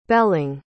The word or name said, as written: belling